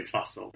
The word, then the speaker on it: Fussell: Well, look, I mean, if I'm trying to be bougie, I say Glyn Fussell, but really, it's just Glyn Fussell.